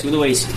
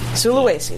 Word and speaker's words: Sulawesi: Sulawesi. – Sulawesi.